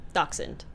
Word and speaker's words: Dachshund: Dachshund. – Dachshund? – Mm-hmm. Wiener dog. – Oh. Is that how you pronounce that? – Yeah.